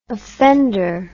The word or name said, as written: offender